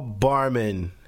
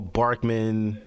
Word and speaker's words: Barman: Am I saying it right? Paul Barkman? Barman? Okay, Paul Barman. – Paul Barman. At least I know how to say his name.